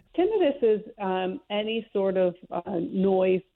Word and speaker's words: tinnitus: I know you're in Western New York, but out here in Colorado, we see so many TV ads now about tinnitus.